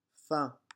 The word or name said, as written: faon